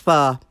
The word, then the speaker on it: pho: I love Vietnamese pho. Uh, it's pronounced pho, not fo, but they are so polite. They never say, it's pho, it's not fo. Americans love saying fo real. It's pho real.